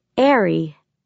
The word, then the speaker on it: are: One A-R-E, pronounced Airy.